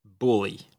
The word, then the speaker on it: bulla: Okay, so according to Google, it's pronounced bully.